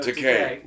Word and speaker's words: Takei: Okay, so Takei rhymes with gay.